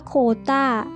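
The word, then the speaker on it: quota: โคตา้า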